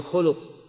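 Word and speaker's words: khuluq (خُلُق): Khuluq, didomahkan kha-nya, dan khuluq artinya akhlak. Kalau difatahkan khaknya, khalq, penciptaan. Nah, poster tubuh. Nah, barakallahu fikum. Jadi kalau akhlak, bacanya khuluq.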